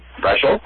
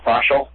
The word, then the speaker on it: Freshel: How do you say that last name? – Freshel. – Freshel. – Rhymes with special. – Yay. Freshel, special.